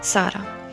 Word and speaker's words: Sara: As I told you, my name is Sara from Algeria, and Sara is a pretty common name but pronounced differently across the globe. And in the Algerian dialect, Sara.